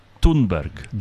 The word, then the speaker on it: Thunberg: By the way, I heard her pronounce her own name the other day, and I think it's only fair that we try to say her name the way she wants it said. It's Greta Thunberg.